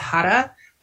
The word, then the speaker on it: Har: There is a specific chant for abundance called, it's pronounced Hara, but it's spelled H-A-R Mantra Girl is a wonderful YouTube show that has like, it's just the audio, but you can do some chanting.